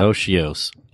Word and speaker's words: otiose: So my word is otios. – How do you spell that? – Otios. O-T-I-O-S-E.